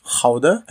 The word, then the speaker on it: Gouda: But in the Netherlands, it's pronounced Gouda, I guess, which is actually a specific town, I think, in the middle of Rotterdam and Amsterdam.